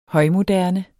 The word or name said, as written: højmoderne